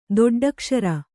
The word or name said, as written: ದೊಡ್ಡಕ್ಷರ